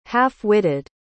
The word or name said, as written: half-witted